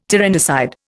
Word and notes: tyrannicide: Indian

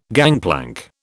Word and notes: gangplank: British English

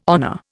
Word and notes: honour: Indian

